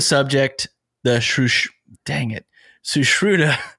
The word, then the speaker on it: Sushruta: It's Sushruta, Sushruta, Sushruta, Sushruta, Sushruta.